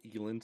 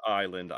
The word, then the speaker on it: Island: Yeah, a lot of people call me Elon sometimes because of the way my name is spelt, but I assure you it's Island, I swear. I've gone by Island all of my life.